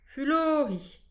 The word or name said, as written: fulorin